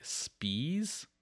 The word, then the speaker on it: Spies: I think in German that becomes just like Spies, but you're right.